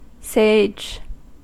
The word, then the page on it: sage: American English